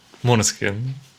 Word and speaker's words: Måneskin: Måneskin.